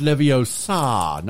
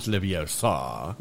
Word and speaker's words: Leviosa: It's pronounced Leviosa, not Leviosa.